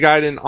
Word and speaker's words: Gaiden: You know, that's the thing about I remember Ninja Gaiden on the fucking NES. Where, you know, my grandmother completely went, well, the American pronunciation is Gaiden, so just go with that.